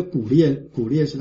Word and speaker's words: 古列: 稍微跟弟兄姐妹报告一下，就是我们圣经里的那些名称都是按照它的原文的发音讲出来的，所以这古列是它正确的发音。